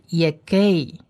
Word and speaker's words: Yegei: 而第二个字母呢是希伯来文字母 Hey，第四个字母也是，那这个字母呢 Hey 发音发和的音，和的音，这第二个字母的这个 Hey 呢它下面有两点冒号，它是不发音的，因为前面第一个字母的母音是短母音，所以这是 Yegei，也就是说这第二个字母的这个 Hey 它是发和的音，那 Yegei这个发赫好像就跳过去了，也 gay。